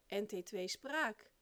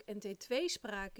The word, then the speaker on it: NT2-spraak: Ze zei namelijk consequent NT2-spraak, met de klemtoon op het laatste deel. Nou weet ik dat NT2-spraak een rare samenstelling is van een afkorting, NT2, en een woord, spraak. Maar als je weet dat in een samenstelling het eerste deel meestal de klemtoon krijgt en in een afkorting het laatste deel, dan is het toch logisch dat de juiste uitspraak NT2-spraak is, dacht ik.